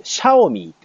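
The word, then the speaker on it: Xiaomi: はい、小さな米と書いて、シャオミーって会社。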